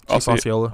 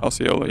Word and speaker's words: Osceola: I'm not going to pronounce Osceola. – Osceola, yeah.